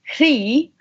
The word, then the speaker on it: Chroi: It's meant to be Chroi in Gaelic, like Irish Gaelic, but we end up kind of saying Chroi or Chroi or, yeah, but it's meant to be Chroi.